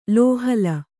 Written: ಲೋಹಲ

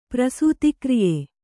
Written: ಪ್ರಸೂತಿಕ್ರಿಯೆ

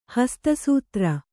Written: ಹಸ್ತಸೂತ್ರ

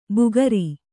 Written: ಬುಗರಿ

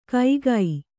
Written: ಕೈಗೈ

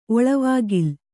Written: ಒಳವಾಗಿಲ್

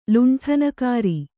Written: ಲುಂಠನಕಾರಿ

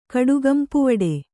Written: ಕಡುಗಂಪುವಡೆ

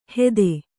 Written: ಹೇದೆ

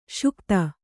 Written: ಸೈಕತ

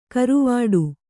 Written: ಗರ್ವಾಟ

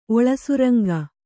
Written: ಒಳಸುರಂಗ

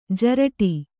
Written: ಜರಟಿ

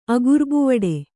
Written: ಅಗುರ್ಬುವಡೆ

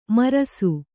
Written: ಮಿರಾಸಿ

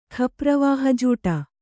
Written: ಖಪ್ರವಾಹಜೂಟ

